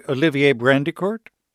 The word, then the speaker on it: Olivier Brandicourt: And Olivier Brandicourt? – That's correct.